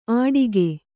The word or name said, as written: ಅಡಿಕೆ